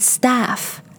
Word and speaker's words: staff: And then staff. Ah, staff. Staff 里面的元音音素是 ah.